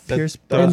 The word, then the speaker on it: Pierce Brosnan: Pierce Brosnan. – No, you said it right.